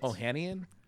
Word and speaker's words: Ohanian: Alexis Ohanian. – Yeah, that's Ohanian.